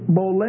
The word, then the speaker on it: boule: The word counsel, the Greek is boule, B-O-U-L-E, it's a long E, B-O-U-L-E, means one's will or purpose, one's design.